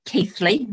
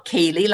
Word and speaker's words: Keighley: So we're based just outside Bradford in Keighley, and it's pronounced Keighley, not Keighley, like everybody thinks.